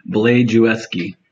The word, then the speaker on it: Blazejewski: Blajewski.